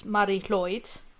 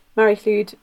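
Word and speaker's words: Mari Lwyd: I think there's several ways of pronouncing it, because I always heard it was Mary Clude. – I think it depends which. Mary Clude, Mary Floyd. It depends which part of Wales.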